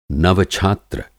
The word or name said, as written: नव-छात्र